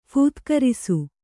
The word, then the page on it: ಫೂತ್ಕರಿಸು: phūtkarisu